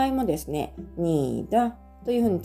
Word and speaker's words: 你的: なのでこの 2 文字で合わせて「にだ」というふうに発音してみてください。